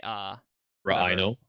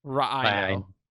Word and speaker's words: Ra'ino: So Karendor also asks, the lighthouse guy, that is to say, Ra'ino. – Ra'ino. – Thank you for saying it correctly. – Well, that's because rhino sounds dumb.